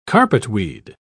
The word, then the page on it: carpetweed: British English